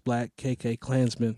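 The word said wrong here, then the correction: BlacKkKlansman: No, just Black Klansman.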